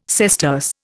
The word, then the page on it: sesterce: Indian